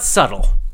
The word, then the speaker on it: subtle: It has a strange spelling, subtle, because it has a B, but we don't pronounce the B. It's actually spelled subtle. It's spelled S-U-B, S-U-B, like boy, S-U-B-T-L-E, T-L-E, S-U-B-T-L-E. It looks like subtle, but that's wrong pronunciation. The correct pronunciation is subtle, subtle. The B is silent.